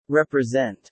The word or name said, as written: represent